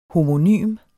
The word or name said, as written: homonym